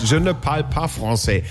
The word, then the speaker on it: je ne parle pas français: Namika, je ne parle pas français. – Nein, das hast du falsch ausgesprochen. Das heißt, je ne parle pas français.